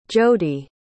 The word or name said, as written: jodi